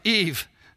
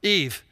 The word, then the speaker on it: Yves: We had a testimony yesterday of a man whose name was Eve. I know, Eve. It was spelled Y-V-E-S. He said, you pronounce the S silent.